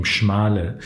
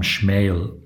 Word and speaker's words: Schmale: our family name Schmale in Arabic is pronounced by some Schmael.